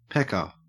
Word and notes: pecker: Australian English

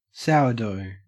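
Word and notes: sourdough: au Australian

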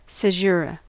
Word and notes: caesura: American English

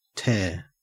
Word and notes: tare: Australian English

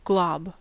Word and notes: glob: American English

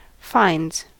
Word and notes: finds: American English

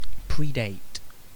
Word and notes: predate: British English

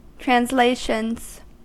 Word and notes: translations: American English

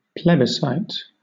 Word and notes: plebiscite: British English

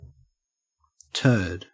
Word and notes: turd: Australian English

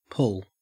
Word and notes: pull: Australian English

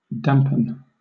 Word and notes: dampen: British English

